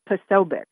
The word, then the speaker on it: Posobiec: It's Posobiec.